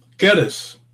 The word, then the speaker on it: Geddes: I would say like lettuce. Geddes. – Geddes.